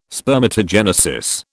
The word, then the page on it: spermatogenesis: British English